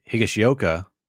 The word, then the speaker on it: Higashioka: And Higashioka, I said it right.